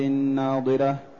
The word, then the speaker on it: ناضرة: ثم قال تعالى: وجوه يومئذ ناضرة من النضارة، أي حسنة بهية مشرقة مسرورة. – التي النضارة والجمال والبهاء والحسن، وهي تنطق بالضاد، والأخرى تنطق بالضاء التي من النظر.